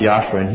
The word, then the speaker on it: Yahshua: Speaking of Yahshua, why don't you turn to the book of Joshua, whose name is pronounced Yahshua in Hebrew.